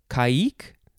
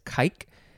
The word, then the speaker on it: caique: Next is, let's see, caique. You could pronounce it that way, or you could just pronounce it as caique. C-A-I-Q-U-E, and the I has the umlaut, the two dots, caique, or caique.